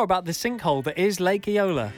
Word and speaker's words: Eola: Lake Eola, have I said that right? – Yeah.